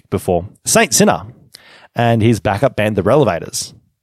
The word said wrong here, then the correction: Revelators: The Revelators. I keep mispronouncing. Yeah. Saint Sinner. And yeah, he has a backup band called The Revelators. There we go. Did it better that time.